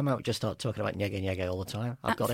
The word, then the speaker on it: Nyege Nyege: Well, I was desperate to go to this festival, Nyege Nyege. You did say it right.